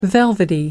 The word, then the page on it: velvety: British English